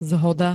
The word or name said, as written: zhoda